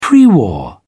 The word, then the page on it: pre war: British English